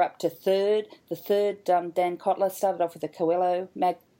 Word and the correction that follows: Coelho: I pronounced that wrong last time, didn't I? How do you say it? – It's Coelho Medallion. It's a Portuguese name, which I stole from Paulo Coelho, who's the author of The Alchemist.